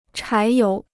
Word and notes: 柴油: chái yóu

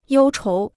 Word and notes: 忧愁: yōu chóu

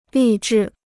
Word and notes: 励志: lì zhì

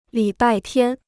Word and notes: 礼拜天: lǐ bài tiān

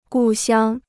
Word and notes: 故乡: gù xiāng

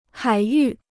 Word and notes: 海域: hǎi yù

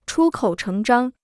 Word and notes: 出口成章: chū kǒu chéng zhāng

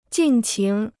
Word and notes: 尽情: jìn qíng

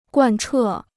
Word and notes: 贯彻: guàn chè

